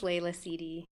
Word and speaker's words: Leila Sidi: My name is Leila Sidi.